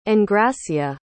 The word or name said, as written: engracia